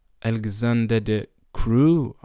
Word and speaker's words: Alexander De Croo: And in Belgium, their Prime Minister, Alexander De Croo, that's how you pronounce his name,